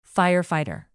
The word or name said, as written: firefighter